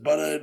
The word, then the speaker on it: Buda: And that's B-U-D-A. People pronounce it Buda, never you name it, but... It is Buda, so you can find it.